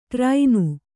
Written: ಟಾರೆಣ್ಣೆ

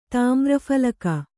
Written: ತಾಮ್ರಫಲಕ

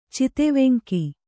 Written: ಚಿತೆವೆಂಕಿ

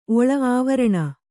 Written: ಅವರ್ಣಿ

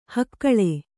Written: ಹಕ್ಕಳೆ

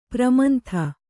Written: ಪ್ರಮಂಥ

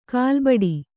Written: ಕಲ್ಲುಬೆಟ್ಟ

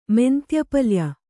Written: ಮೆಂತ್ಯಪಲ್ಯ

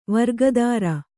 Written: ವೃಕೋದರ

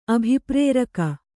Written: ಅಭಿಪ್ರೇರಕ

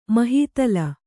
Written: ಮಹಾತಳ

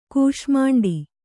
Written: ಕೂಷ್ಮಾಂಡಿ

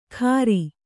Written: ಖಾರಿ

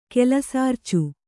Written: ಕೆಲಸಾರ್ಚು

